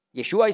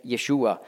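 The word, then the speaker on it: Jeschua (ישוע): Oder ihn Jeschu nennen, anstatt Jeschua. Jeschua ist die hebräische Aussprache für Jesus. Griechisch ist die Aussprache Jesus, hebräisch Jeschua. Aber die sagen Jeschu.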